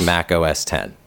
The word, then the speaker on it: Mac OS X: By the way, Mac OS X apparently is pronounced Mac OS 10.